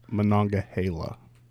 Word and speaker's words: Monongahela: also known as Monongahela, um, which is a high rye content, usually you know 90 plus 95. – Monongahela. No, not Gila. Monongahela.